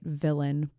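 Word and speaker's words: Vylan: I love Bob Villain. And yes, I'm saying that right, Villain, but with a V-Y-L-A-N, just like Bob Dylan, but with a V.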